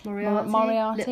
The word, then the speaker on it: Moriarty: I'll give an example of Leanne Moriarty. Is that how we say her name? – Moriarty.